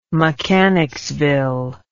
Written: mechanicsville

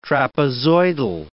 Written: trapezoidal

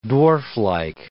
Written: dwarflike

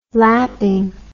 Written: lapping

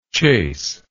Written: chaseable

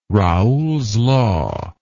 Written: Raoult's law

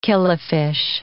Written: killifish

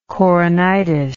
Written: coronitis

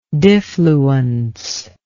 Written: difluence

